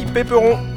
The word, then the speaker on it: Péperon: Nom de famille, Péperon. Ça s'écrit comme ça se prononce.